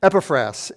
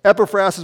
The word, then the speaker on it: Epaphras: Epaphras is how they pronounce it in Greek. So Epaphras is what I'll go with.